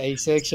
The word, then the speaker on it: Asics: He coached me as a kid and he said he whipped out his uh, Asics, or do you, do you pronounce it Asics? Yeah. Yeah, Asics. Yeah, he just whipped his out there like black, and I was like, they're literally 25 years old boots.